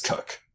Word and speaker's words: Coke: My dad's name, K-O-C-H, Cook. Yeah. Married my mom, who is C-O-K-E, also pronounced Cook. – That's so, so bizarre. – It is genuinely, yeah, it's really weird. But yeah, no, that is how I pronounce it.